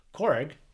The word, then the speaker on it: Korg: And actually, you're going to see one of the most famous ones, Korg. If that's how you say his name. – Oh, yeah. – K-O-R-G.